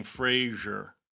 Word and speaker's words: Fraser: By the way, we've been saying it wrong. I'm sorry to interrupt you, but I got yelled at on the internet. It's Fraser. We've both been saying Fraser. – It's Fraser? – Fraser, like Fraser. – Like Laser. – Like Laser. Fraser.